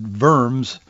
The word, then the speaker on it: Worms: Today in 1521, Martin Luther went before the Diet of, it's spelled Worms, but it's pronounced Verms.